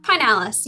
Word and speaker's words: Pinales: Pinales, yes. – Pinales. What did I say? Pinales? Pinales. – Pinales, like the pine tree? – Of course.